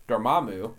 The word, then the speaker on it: Dormammu: But also, as we learned to pronounce it finally, Dormammu.